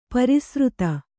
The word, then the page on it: ಪರಿಸ್ರುತೆ: pari srute